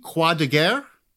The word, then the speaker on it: Croix de Guerre: which begs the question, did I pronounce those right? Did that sound? – That sounded good to me.